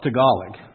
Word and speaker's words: Tagalog: It's T-A-G-L-A-G. Now, my natural tendency is to pronounce that Taglag. But no, it's Tagalog.